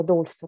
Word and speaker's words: доульство: Перед тем, как мы начнем обсуждать немножко вот про доульство, расскажи, правильно говорю, вот слово доульство так говорят? то... – Да, да? конечно.